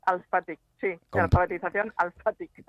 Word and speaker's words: Alphatic: Con PH, o sea que lo pronunciamos como ha empezado hoy el programa mi compañera Andrea diciendo Facebook, pues esto diremos que es Alphatic con H intercalada.